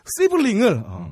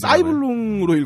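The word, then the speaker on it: sibling: 시블링을 해비조님이 사이블룽으로 읽었다면서. – 사이블룽이라고 읽진 않았고요. 예, 예. – 사이블링으로 읽었어요.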